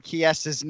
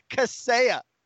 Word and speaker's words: Chiesa: Dude, I laugh because I've never heard Chiesa's name butchered that badly. He said Kaseya.